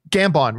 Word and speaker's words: Gambon: Gambon, right.